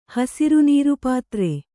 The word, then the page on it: ಹಸರುನೀರುಪಾತ್ರೆ: hasaru nīru pātre